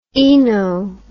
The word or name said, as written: oeno